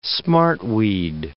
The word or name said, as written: smartweed